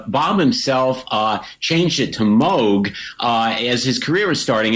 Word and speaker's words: Moog: And he said, well, we can use the original, you know, German-Dutch pronunciation. Moog.